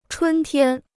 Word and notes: 春天: chūn tiān

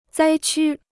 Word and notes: 灾区: zāi qū

